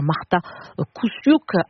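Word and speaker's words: Marta Kostyuk: Et puis les huitièmes de finale à Roland-Garros, marqués aujourd'hui par l'élimination de la numéro 3 mondiale, Iga Świątek, sortie par Marta Kuciuk. À 23 ans, l'Ukrainienne jouera son premier quart de finale, porte d'Auteuil, pardonnez-moi pour ma prononciation ukrainienne. – Elle était absolument parfaite, Yasmina Adila, pour l'info.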